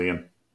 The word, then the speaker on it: Ioan: I think it's just Ian. I think you pronounce it Ian.